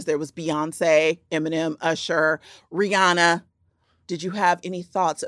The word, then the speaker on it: Rihanna: You know, as you know, I believe that she kind of went away because we never pronounced her name correctly one time. She was like, well, I'm done. It's Rihanna. – It is Rihanna, but she says she don't care if you call her Rihanna.